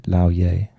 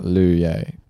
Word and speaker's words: Lou Ye: It's not Lu Ye. It's Lau Ye.